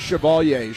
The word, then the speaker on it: Chevalier: Her name is Chevalier. She doesn't know how to pronounce it, but I do. And so does Maurice. Maurice Chevalier.